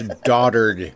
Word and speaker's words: dotard: It must not have been somebody smart, because that's not how you pronounce it, son. – It's dotard. – It will forever be dotard.